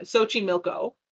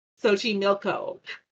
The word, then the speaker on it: Xochimilco: M-E-A-L? – Yeah, like milk? A meal. – But you said milk, like with an I. Xochimilco. – Xochimilco. – Meal. – Meal. What is happening? – You gotta do motor a long E.